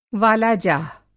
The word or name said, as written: वालाजाह